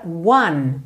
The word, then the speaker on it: one: Essa palavra one, porque o E final não é pronunciado e o começo não é exatamente o som de uma letra O, ok? Você pensa one. One, one day, maybe one day, ok?